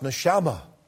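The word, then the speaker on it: neshama (נשמה): The word for breath in the Hebrew language is pronounced neshama.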